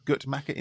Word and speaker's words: Guttmacher: And yet, figures from the Guttmacher, am I saying that right, Dad? Guttmacher Institute? – That's right.